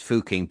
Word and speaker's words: Fucking: The Austrian town of Fuching, spelt F-U-C-K-I-N-G, but pronounced Fuching by them and by me for reasons of broadcastability, changed its name to Fugging after residents became tired of seeing people having sex beside their road sign.